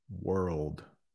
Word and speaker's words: world: But let me pronounce those three words right in a row so you can hear the difference. Word, wood, world. We will talk more about world later. Word, wood, world. There's that R and the L again, like Mode was talking about.